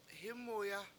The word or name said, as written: hImuya